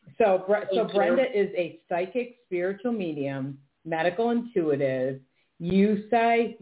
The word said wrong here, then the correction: Usui: I don't know how you pronounce that. – Usui. – Oh, I was way off.